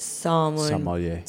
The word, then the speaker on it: sommelier: Best sommelier. Is that how you say it? Sommelier. – Yes, actually, that's what it is.